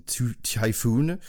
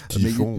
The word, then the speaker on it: typhon: Donc un, un, fin de, de, poule euh, fantôme, on va dire, avec trois matchs annulés au cause du d'une typhoon, euh, que j'arrive à prononcer pas en, français. – Typhon.